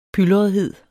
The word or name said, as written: pylrethed